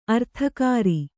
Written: ಅರ್ಥಕರಿ

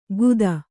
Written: ಖುದಾ

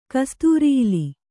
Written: ಕಸ್ತೂರಿಇಲಿ

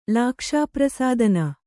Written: ಲಾಕ್ಷಾಪ್ರಸಾದನ

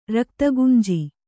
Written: ರಕ್ತಗುಂಜಿ

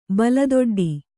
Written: ಬಲದೊಡ್ಡಿ

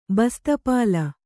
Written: ಬಸ್ತಪಾಲ

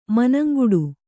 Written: ಮನಂಗಿಡು

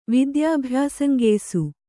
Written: ವಿದ್ಯಾಭ್ಯಾಸಂಗೆಯಿಸು

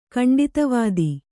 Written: ಖಂಡಿತವಾದಿ

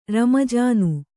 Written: ರಮ್ಮಜಾನು

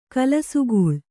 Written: ಕಲಸುಗೂಳು

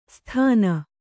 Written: ಸ್ಥಾನ

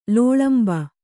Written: ಲೋಳಂಬ

